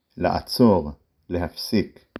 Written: לעצור

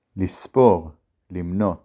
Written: לספור

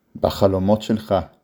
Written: בחלומות

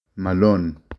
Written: מָלוֹן

